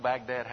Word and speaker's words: Baghdad: Did you know Baghdad has a silent H in it? I hadn't paid attention to that before. B-A-G-H-D-A-D. But I typed in B-A-G-D-A-D.